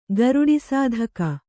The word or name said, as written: ಗರುಡಿಸಾಧಕ